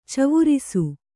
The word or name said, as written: ಚವುರಿಸು